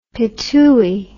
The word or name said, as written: pitooi